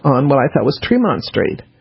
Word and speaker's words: Tremont: But and it's spelled Tremont. It's pronounced Tremont.